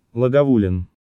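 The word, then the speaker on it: Lagavulin: Второе. Проще, чем кажется на первый взгляд, Лагавулин.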